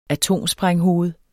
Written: atomsprænghoved

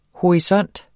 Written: HORISONTERNE

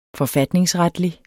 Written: forfatningsretlig

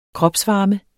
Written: kropsvarme

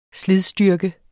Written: slidstyrke

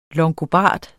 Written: longobard

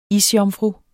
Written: isjomfru

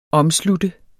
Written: OMSLUTTENDE

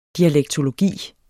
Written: dialektologi

